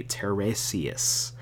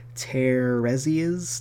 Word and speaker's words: Teiresias: They were being led there essentially to fight off what was called the Tiresias. And I know I said that completely wrong, but it's spelled T-E-I-R-E-S-I-A-S. So Tiresias, something like that.